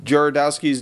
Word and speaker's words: Jodorowsky: Jodorowsky's Dune. – Yeah. – How do you pronounce it? – Jodorowsky. – You pronounced it Jodorowsky.